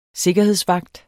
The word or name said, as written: sikkerhedsvagt